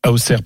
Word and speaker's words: Auxerre: Donc, les joueurs euh, voudront gagner à, à Auxerre. À Auxerre. Auxerre, pardon. Important.